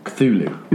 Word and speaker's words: Cthulhu: Cthulhu.